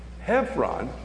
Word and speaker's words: Hebron: Hebron, you may pronounce it Hebron, H-E-B-R-O-N. The B has a V sound in Hebrew.